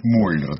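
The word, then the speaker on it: Müller: Вполне возможно, что на самом деле звали его, я думаю, не Миллер, а Мюллер. Миллер – это он в Соединенных Штатах, вот в его свидетельстве о смерти. Значит, вполне возможно. – Как прокурор, на самом деле, произносится как Мюллер.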